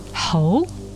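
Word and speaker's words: hou (猴): Bee in Chinese pronunciation, feng, meaning bestow high office. And monkey, pronounced hou, means a marquis.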